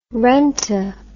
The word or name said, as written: renta